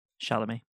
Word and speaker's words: Chalamet: Go watch a Timothée Chalamet movie. I don't even know if I'm saying his name right. – Chalamet. – Oh, yeah. I forgot the M on there.